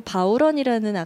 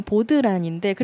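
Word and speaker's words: bodhrán: 악기 중에서도 바우런이라는 악기를 그 원래 우리가 써진 대로 읽으면 보드란인데 그래갖고 네네. 되게 사람들이 뭐 보드란이라고 실제로 예전에 막 악기 파는 사이트에서도 썼는데 실제로는 그걸 바우런이라고 읽거든요.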